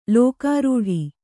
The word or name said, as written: ಲೋಕಾರೂಢಿ